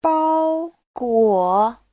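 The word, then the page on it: 包裹: bao1guo3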